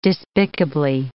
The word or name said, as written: despicably